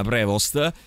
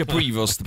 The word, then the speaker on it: Prevost: E in realtà lui stesso ha fatto un tutorial, cioè un video dove lui dice no, si dice Prevost, perché lui lo pronuncia all'inglese, ma in realtà è un nome d'origine francese, quindi andrebbe pronunciato senza la S e la T finale.